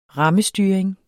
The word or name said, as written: rammestyring